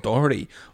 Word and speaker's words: Doherty: Så Matt Doherty, så H-en må inn på en eller annen måte, men en H mellom to vokaler, det er litt tricky.